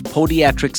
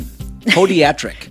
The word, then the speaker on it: podiatric: I've got Dr. Nicole Bauerly, a doctor of podiatric surgery. Is that the right way to say it? – Doctor? That is correct. Yes. – That's a hard one. Podiatric. – Podiatric surgery, yes.